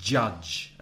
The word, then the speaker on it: judge: The same sound that you would find in words like June, July, January, jazz, and judge, juez, has the vowel sound A, as in up, judge. So practice that at home. Listen to me, judge, and repeat the word judge.